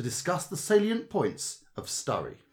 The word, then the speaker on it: Sturry: Welcome to the audio guide of this tour of the town of Sturry, pronounced to rhyme with slurry.